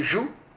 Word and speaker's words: joug: Le joug, je le prononce correctement, le joug, bon, épelé J-O-U-G.